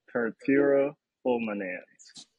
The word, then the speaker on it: purpura fulminans: And it's like 1% of people may have heard of it, but it's called purpura fulminans. All right, and it's spelled P-U-R-A F-U-L-M-I-N-A-N-S.